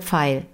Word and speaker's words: Pfeil: Der Pfeil mit einem P. – Der Pfeil vorne. zeigt nach links. – Ja, der, der Pfeil. Der du Pfeil, recht. PF wird ausgesprochen im ja. Deutschen. Mhm.